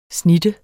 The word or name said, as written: snitte